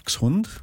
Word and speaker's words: Dachshund: So the Germans would call it a duck. Dachshund. – Dachshund.